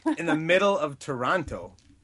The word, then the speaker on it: Toronto: It's Toronto. – Toronto. – Toronto. – In the middle of Toronto. – There you go. You got it. Nobody awesome. Pronounces the second T. Nobody pronounces the second T.